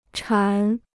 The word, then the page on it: 馋: chán